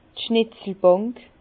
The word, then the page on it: d' Schnetzelbànk: Bas-Rhin; Herrlisheim